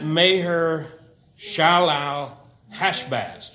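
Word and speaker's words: Maher-shalal-hash-baz: It's phonetic, so you just pronounce what's in between the dashes slowly and then put it all together. But that Meher Shalal Hashbaz, just say it like that, and then you can say it faster as you get better.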